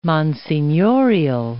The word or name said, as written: monsignorial